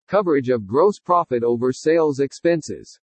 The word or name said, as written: COVERAGE OF GROSS PROFIT OVER SALES EXPENSES